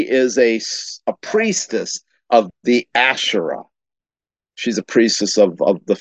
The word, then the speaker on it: Asherah: Now that's that word I can pronounce correctly and spell it right. The Asherah, which is a term that's in the Old Testament, A-S-H-E-R-A-H.